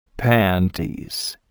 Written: panties

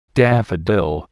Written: daffodil

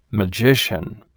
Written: magician

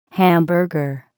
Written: hamburger

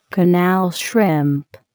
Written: canal shrimp

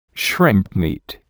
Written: shrimp meat

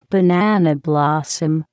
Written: banana blossom

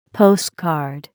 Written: postcard

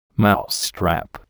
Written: mousetrap

